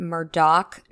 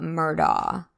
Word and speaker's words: Murdaugh: So first of all, the name is pronounced Murdoch, not Murdaw. If you hear a reporter saying the name without the k at the end, they're basically admitting to having no sources in Hampton County and not knowing what they're talking about.